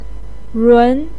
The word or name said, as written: run2